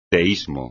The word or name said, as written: teísmo